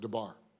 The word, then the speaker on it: dabar: The first word is the Hebrew word debar, or as you would pronounce it, debar. D-A-B-A-R.